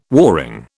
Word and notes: warring: British English

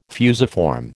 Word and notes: fusiform: American English

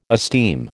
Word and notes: esteem: American English

